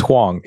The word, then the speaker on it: Huang: It's Huang, yes.